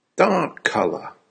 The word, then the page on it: dark colour: American English